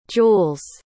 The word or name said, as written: jewels